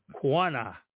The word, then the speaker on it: Qanna: And the way it's pronounced is Quana, Q-A-N-N-A.